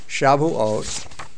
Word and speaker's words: Shavuot: The modern Hebrew name is Shavuot, S-H-A-V-U-O-T, which is the way it's spelled in your book.